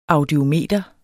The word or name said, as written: audiometer